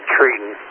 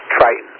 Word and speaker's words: Triton: You're saying Triton, like a tree. It's Triton, like the word I, like me and I. You know, it's Triton.